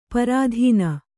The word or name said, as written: ಪರಾಧೀನ